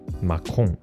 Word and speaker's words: Macron: 它的发音跟法国总统马克宏哎不一样哦。法国总统是 Macron，全名是 Emmanuel Macron。那马卡龙是 macaron，差一个字母而已哦，但念起来哎真的是很不一样哦。